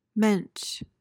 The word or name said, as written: mensch